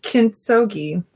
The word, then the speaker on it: Kintsugi: I believe it is pronounced Kintsugi, hard G.